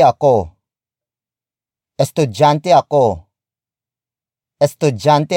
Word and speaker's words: estudyante: Estudiante, estudiante, estudiante is spelled as E S T U D Y A N T E. It means student. We borrowed it from Spanish.